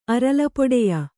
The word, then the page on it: ಅರಲ್ಪೊಡೆಯ: Kannada